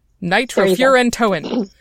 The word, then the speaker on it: Nitrofurantoin: Nitrofurantoin. – Well done. Usually known in the American market, though not this particular formula that we're going to talk about, as Macrobid. Nitrofurantoin is a very old drug that is primarily used for urinary tract infections.